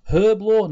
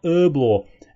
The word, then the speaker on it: Herblore: The H is not silent in Herblore. It is pronounced Herblore, not Herblore.